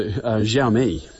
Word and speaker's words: Xiaomi: Xiaomi? – Yeah, not bad. So Xiaomi. – Xiaomi. – So pretty close.